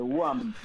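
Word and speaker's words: Wham!: esa banda llamada WOM, que suena, parece, lo pronuncio tan mal que parece que estoy hablando de la Unión Obrera Metalúrgica, estamos hablando de WAM, ¿no? WHAM con signo de exclamación.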